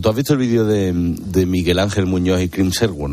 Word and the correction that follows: Hemsworth: ¿no? ¿Cómo se dice? – Hemsworth.